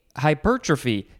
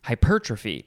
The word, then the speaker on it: hypertrophy: I thought that the enunciation or the emphasis was might be on a different syllable, but I couldn't figure it out in my brain. Hypertrophy.